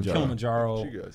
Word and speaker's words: Kilimanjaro: And you climbed Mount Kilimanjaro.